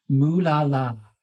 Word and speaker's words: moolala: It is pronounced moolala. Moolala. – Of course it is. – With an M.